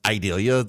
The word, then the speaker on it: Idalia: I guess it's pronounced Idalia, like Vidalia, but Idalia.